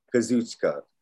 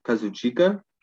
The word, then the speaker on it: Kazuchika: Kazuchika. Oh, God. It's going to be hard for me. – Some people also pronounce it Kazuchika.